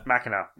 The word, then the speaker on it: Machina: My list was Anthem, Wargroove, and Demon X Machinima, which... – Machina, rather, yes.